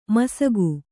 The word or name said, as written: ಮುಸುಕ